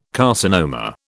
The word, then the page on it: carcinoma: British English